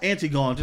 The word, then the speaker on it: Antigone: It's pronounced Antigone, just for future reference.